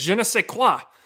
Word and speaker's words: je ne sais quoi: And I like this one poster, 2600 Bro, who wrote, Centro and Hocker, though, both have that je ne sais quoi. Is that how you say it? – No, it's not how you say it. Je ne sais quoi.